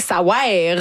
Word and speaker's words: ça wear: Ça veut rien, ça wear. Vraiment, ça s'écrit de même, il faut le prononcer comme ça.